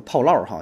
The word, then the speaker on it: 炮烙: 炮烙哈，炮烙就是我一般都管叫炮烙哈。你看标准发音是炮烙啊，呃，这也是苏妲己发明的哈。具体做法就是让一个人走在一个烧红的铜柱子上啊，就是一个大。